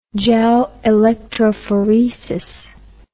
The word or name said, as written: gelelectrophoresis